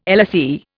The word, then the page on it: LSE: Indian